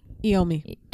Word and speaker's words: Iommi: Iommi.